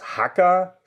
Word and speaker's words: Jaca: Jaca schreibt sich J-A-C-A. Wir haben gelernt, dass das J als H ausgesprochen wird, relativ hart.